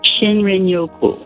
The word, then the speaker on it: Shinrin-yoku: I think it's called shrin-yoku. Is that how you pronounce it? The Japanese term for wood air bathing. – Shinrin-yoku. – Shinrin-yoku.